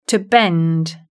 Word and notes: to bend: British English

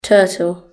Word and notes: turtle: British English

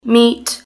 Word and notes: meat: British English

